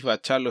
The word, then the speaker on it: Bachalo: Está haciendo lo que pasaba a Chris Bacalo, yo pienso que es Chris Bacalo, pero sí, Chris Bacalo es como he escuchado que se pronuncia.